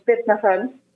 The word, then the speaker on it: Birna Hrönn: Yeah, my name is Birna Hrönn.